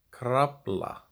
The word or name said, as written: Krafla